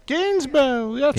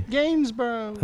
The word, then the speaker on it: Gainsborough: Gainsborough. – That's how they pronounce it. That's the British pronunciation. – Okay, continue. – Gainsborough.